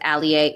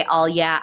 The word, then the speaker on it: Alyea: But you can find me at Hannah Allier. That's Hannah with two H's. Yes, the palindrome. Last name spelled A-L-Y-E-A. It is pronounced Allier. If you say all yeah, I won't get mad.